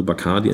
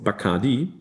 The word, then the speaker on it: Bacardi: The Bacardi cocktail, or pronounced Bacardi, common in New York and Europe, is unknown in Cuba. The proper pronunciation of the name Bacardi stresses the last...